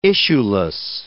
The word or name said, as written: issueless